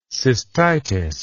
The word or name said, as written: cystitis